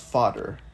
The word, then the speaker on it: Vader: But it's pronounced it's pronounced fodder, but it's spelled like Vader, like Darth Vader.